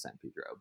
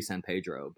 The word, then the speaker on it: San Pedro: And as I like mispronounce San Pedro, but you know, that's a perfect example, right? Where it's like, you know, it's like it should be San Pedro, but it's San Pedro.